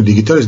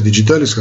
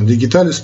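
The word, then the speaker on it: digitalis: дигиталис пурпурея ну и дигиталис, диджиталис, как хотите называть, ну по латыни все-таки, наверное, правильно произносить, конечно, вспоминаю, какая латынь, но неважно, дигиталис пурпурея.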